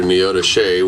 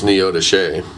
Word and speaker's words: Neodesha: I think they got married in Neota Shea, which everybody that doesn't live in that area pronounces it completely wrong. – What's it called? – It's spelled Neodesha, but it's Neota Shea.